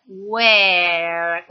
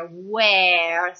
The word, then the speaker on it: where: E ancora, altra precisazione, la E di were è una E molto chiusa, diversamente dalla E dell'avverbio interrogativo dove, che in inglese si dice where. Qua è proprio la rana dalla bocca larga, where, sempre la U iniziale, poi where.